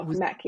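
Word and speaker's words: Mech: There was an article there by David, I don't know how to pronounce his last name, but Meck or Meech. – Oh, Meck, yep.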